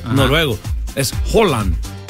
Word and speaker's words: Haaland: Todavía no lo estoy diciendo bien, pero se dice, en vez de decir la A, la doble A se convierte como en O. – Oh, wow. – Holland.